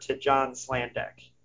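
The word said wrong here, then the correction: Sladek: who's a... – Slaydeck. – Of course I pronounced it wrong.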